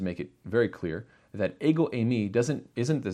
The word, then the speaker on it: ego eimi: We actually find that the statement here, "I am," made by Jesus, was recorded in Greek, and it's pronounced in the Greek "ego eimi."